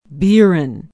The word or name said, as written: biron